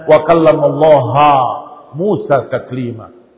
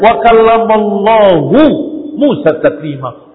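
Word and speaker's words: wa kallamallahu Musa taklima (وَكَلَّمَ اللَّهُ مُوسَىٰ تَكْلِيمًا): Sepanjang masa mereka berkata dan membaca ayat wa kallamallahu Musa taklima, mereka membacanya wa kallamallaha Musa taklima. Lafaz Allah dengan fathah, kallamallaha.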